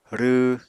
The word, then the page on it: rww: Apatani